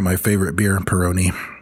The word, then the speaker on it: Peroni: Peroni.